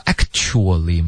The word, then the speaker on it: actually: 然后这里它就顿了一下这个 actually 呢就顿了没有连读 what I actually, 这个 a, 就没有连读因为他想要强调所以这里他就发 actually 然后这一个字呢 actually 很多的学生会说不是发 actually 吗有一个 ch 的音这个 t 其实会省略然后 k 直接连到 sh